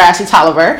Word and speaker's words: Ashley Tolliver: So the topic for Ashley T or Ashley Tolliver.